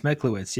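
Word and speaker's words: Mechlowicz: I don't want to butcher his last name, but Scott Meklowitz. – I think it's Meklowitz.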